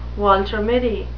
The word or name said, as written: Walter Mitty